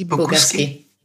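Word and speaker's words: Bogusky: Also du warst bei ähm, BBH London, Jung von Matt, Crispin Porter und wie spricht man das eigentlich aus? Bogaski? – Bogaski. – Bogaski, Miami, genau.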